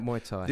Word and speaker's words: Muay Thai: And this Muay Thai, did I pronounce that right? – Muay Thai.